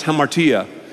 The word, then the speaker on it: hamartia: Sin, in the original language, is pronounced hamartia.